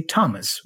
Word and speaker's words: Thomas: The exceptions are Thomas, the name. It is written TH, but we don't say Thomas. We say Thomas with a T, ta-ta, Thomas.